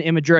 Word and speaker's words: Imgur: Oh, is that how you pronounce that? I-M-G-U-R is Imgur? – Yes, I think it is. – For years I've just been saying Imgur.